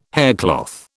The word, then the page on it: haircloth: British English